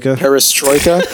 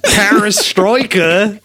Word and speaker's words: perestroika: How do you pronounce that in English? – Perestroika. Perestroika. We get almost Australian.